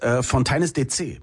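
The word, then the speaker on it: Fontaines D.C.: Und du hast es wirklich falsch ausgesprochen, weil es ist Fontaines DC.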